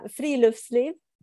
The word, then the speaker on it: friluftsliv: Is that how you say it? – That's pretty close. – Okay. – And don't worry, you're in good company. Everybody worries about butchering this word. But yeah, friluftsliv.